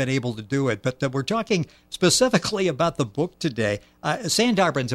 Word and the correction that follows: Sundarbans: am I saying that correctly? – Sundarbans. – Okay, I got the accent in the wrong place.